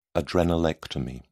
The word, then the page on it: adrenalectomy: British English